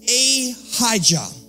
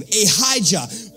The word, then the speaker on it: Ahijah: Among close to him was Ahijah. That's how you say his name. Ahijah, the grandson of Eli, the prophet, or the priest, the high priest.